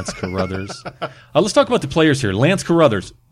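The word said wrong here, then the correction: Crouther: Am I saying that right? – I think it's Lance Crowther.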